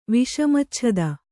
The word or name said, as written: ವಿಷಮಚ್ಛದ